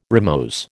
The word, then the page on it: rimose: American English